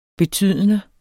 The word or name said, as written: betydende